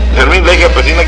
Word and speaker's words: Fermín Leija Pesina: Esto es lo que tenemos, Fermín Leija Pesina, quien es líder estatal del Frente Popular Francisco Villa, ¿ya lo dije bien? – Sí, lo dijiste bien.